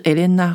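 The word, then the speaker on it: Helena: 这个赫莲娜这个品牌的创办人，这个 HR 呢就是 Elena Rubinstein。我的发音就是法文发音， Elena 就是赫莲娜， Rubinstein 就是她的姓。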